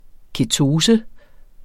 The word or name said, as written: ketose